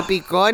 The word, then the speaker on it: picó: Te pico, zancudo draculón. Te pico, te pico. – Te picó, niña, es con acento en la O.